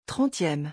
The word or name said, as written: 30eme